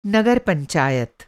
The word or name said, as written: नगर पंचायत